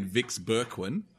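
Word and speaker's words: Vieux-Berquin: Okay, so it's, well, to the Aussies, it would have been Vix Berquin. And I think in French it's something like Va Berquin, something like that. – Sounds good. I'm going to go with that. – It's V-I-E-U-X hyphen B-E-R-Q-U-I-N.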